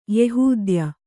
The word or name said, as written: ಯೆಹೂದ್ಯ